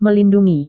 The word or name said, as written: melindungi